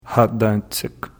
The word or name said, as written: Aataentsic